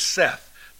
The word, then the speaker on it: saith: And by the way, when you read that word S-A-I-T-H, the correct pronunciation is Seth, not saith, saith.